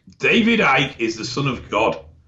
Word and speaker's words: Icke: David, and his last name is I-C-K-E. David Ick? – David Icke.